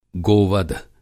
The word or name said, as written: गोवध